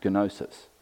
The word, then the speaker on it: gnosis: So you had these Gnostic teachers, that's a silent G, G-N-O-S. It comes from the Greek word gnosis. In Greek, there's no silent letters, so it actually is pronounced gnosis.